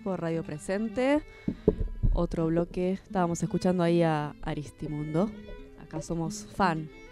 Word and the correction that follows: Aristimuño: ¿Lo dije bien? – Aristimuño. Bien. Aristimundo le mandó.